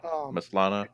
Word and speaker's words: Maslany: Yeah, Maslany.